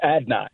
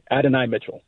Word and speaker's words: Adonai Mitchell: It's Adonai. So just Adonai. Adonai Mitchell. – So it is three syllables, though. It's not two, Adonai. It's Adonai.